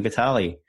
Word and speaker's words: Vitale: And today I am joined with Joey Vitale.